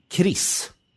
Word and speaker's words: KRIS: Och du identifierar då framförallt Broderskapsrörelsen och KRIS. – Mm. – Uttalas det så? – Ja, det gör det.